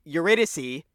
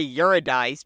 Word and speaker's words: Eurydice: My favorite scene in the movie is whenever he goes to Eurydice, which I love that this fancy schmancy restaurant is mispronouncing that Greek character. It's Eurydice. But they say Eurydice because they don't fucking know. They don't care.